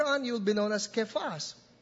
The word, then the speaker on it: Kephas: You will be called Kephas," or as what American preachers would pronounce, Cephas. In the way it's rendered in Greek, it's Kephas.